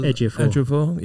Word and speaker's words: Ejiofor: Chiwetel Ejiofor.